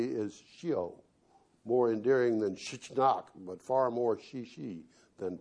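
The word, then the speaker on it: shichinok (щенок): To be fair, the Russian word for puppy, as best I can determine, is pronounced something like shichinok.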